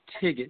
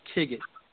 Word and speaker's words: TGID: Okay, well, first of all, just for those who are listening, the brand is pronounced Tigit. That's how you pronounce it, Tigit, which is T-G-I-D.